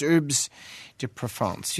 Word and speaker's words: Herbes de Provence: But if you're French, it's Herbes de Provence. Do you think they say herbs? No, they pronounce it. No. – They're French. They don't pronounce any H's in France. I don't even know if they can say H.